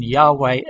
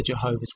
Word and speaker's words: Yahweh: while inclining to view the pronunciation Yahweh as the more correct way, we have retained the form Jehovah because of people's familiarity with it since the 14th century. Even Mr. Stephen T. Byington, from the Bible in Living English published by the Jehovah's Witness Watchtower and Bible Tract Society, in the preface, states that the pronunciation Jehovah is a blunder.